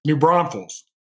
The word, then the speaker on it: New Braunfels: He's based in New Braunfels. I don't know if I said that right. – You're close. – How do you say it? – New Braunfels. – New Braunfels.